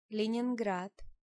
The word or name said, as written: Leningrad